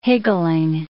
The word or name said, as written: higgling